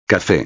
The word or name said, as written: cafe